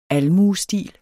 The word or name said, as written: almuestil